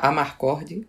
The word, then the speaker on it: Amarcord: sei, eu nunca sei como se fala, mas é Amarcord ou é Amacord, não sei onde tá, se eu tenho R. Amarcord. Amarcord. É. Amarcord, pronto.